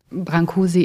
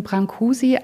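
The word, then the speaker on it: Brâncuși: Und eine wichtige Sache, in Rumänien wird der Brancus ausgesprochen. Wir sprechen ihn Brancusi aus, die französisch eingedeutschte Variante, auch weil er sich selber, nachdem er 1905 nach Paris gekommen ist, so genannt hat.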